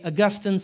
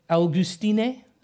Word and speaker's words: Augustine: Some say Augustine, some say Augustine. I say Augustine.